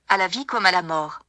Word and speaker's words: à la vie comme à la mort: à la vie comme à la mort.